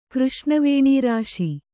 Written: ಕೃಷ್ಣವೇಣೀರಾಶಿ

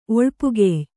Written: ಒಳ್ಪುಗೆಯ್

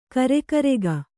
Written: ಗುರಿಗಾರಿಕೆ

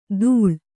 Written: ಧೂಳ್

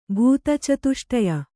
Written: ಭೂತಚತುಷ್ಟಯ

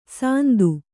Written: ಸಂದು

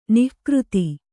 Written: ನಿಕೃತ

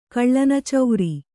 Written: ಕಳ್ಳನಚೌರಿ

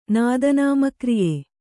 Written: ನಾದನಾಮಕ್ರಿಯೆ

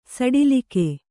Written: ಸಡಿಲ್ಕೆ

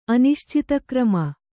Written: ಅನಿಶ್ಚಿತಕ್ರಮ

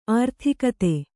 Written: ಅರ್ಥಗತಿ

